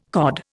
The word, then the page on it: COD: Indian